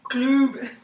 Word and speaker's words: Cloob: It's not C-L-U-B, it's Kloob. C-L-O-O-B.